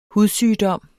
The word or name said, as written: hudsygdom